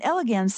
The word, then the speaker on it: elegans: And elegans sounds like the word elegant, and I think it's because they're so beautiful.